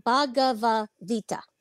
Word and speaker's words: Bhagavad Gita: Bhagavad Gita.